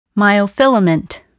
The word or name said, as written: myofilament